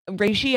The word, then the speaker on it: reishi: OP is an asshole, but miss me with that reishi business. I'm not sure how to say this word. It's R-E-I-S-H-I.